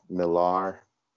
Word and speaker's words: Millar: And I am also on Facebook, Ajmal Millar.